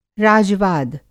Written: राजवाद

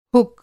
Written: हुक

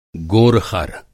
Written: गोरख़र

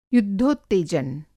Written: युद्धोत्तेजन

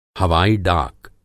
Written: हवाई-डाक

